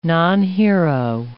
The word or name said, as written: nonhero